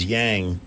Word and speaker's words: Yang: And Andrews Yang. I'm sorry, did I pronounce that? It rhymes with what? – Wang!